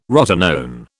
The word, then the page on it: rotenone: British English